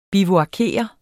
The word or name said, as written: bivuakere